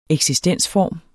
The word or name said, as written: eksistensform